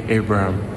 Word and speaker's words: Abraham: The correct way to say my name is Tammy Abraham.